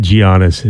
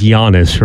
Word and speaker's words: Giannis: I said Giannis. It's pronounced Giannis, right? – I think most people call him Giannis.